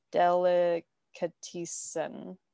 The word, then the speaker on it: Delicatessen: But yeah, so today we are going to be talking about the 1991 dark comedy film Delicatessen, right? Delicatessen. – Well, I found out because he said it, and it's actually pronounced delicatess. – Okay. – The N at the end.